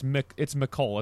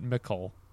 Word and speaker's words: Mecole: It's Mecole. It's like Nicole, but Mecole.